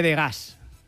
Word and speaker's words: Degas: Venga, venga, y vamos a hablar de las bailarinas de Degas. He dicho Degas para que admiréis mi pronunciación francesa, ¿vale?